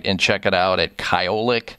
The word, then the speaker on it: Kyolic: I want people to know also that what Dr. Lavelle and I are talking about is Kyolic Aged Garlic Extract.